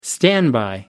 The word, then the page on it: standby: American English